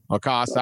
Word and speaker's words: Lacoste: Lacoste.